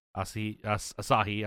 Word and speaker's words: Asahi: I've had, Asahi. – I always say it wrong. – You always say it like acai, like an acai bowl.